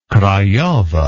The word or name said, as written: craiova